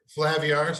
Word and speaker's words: Flaviar: So it's called Flavor. – Flaviar? – Flaviar. Sorry. Thank you. As soon as I was like, dang it, I'm saying that wrong.